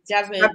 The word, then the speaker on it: Javid: Oh, Javid.